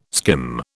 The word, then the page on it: skim: British English